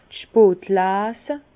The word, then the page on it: d' spot Leese: Bas-Rhin; Herrlisheim